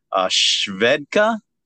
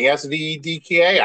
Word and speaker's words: Svedka: But I also got one of my favorites, which is, I don't know if I'm pronouncing it right, the name of the vodka, Svedka. – Yeah, I think it's a Swedish vodka, right? I've seen that with the big blue writing, Svedka. I think you're saying it right.